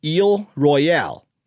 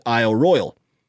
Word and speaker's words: Isle Royale: If you want to make it English, you call it Isle Royale. The incorrect way, which is often how I do it, is pronouncing it Isle Royale, because that's making the first word English and the second word French.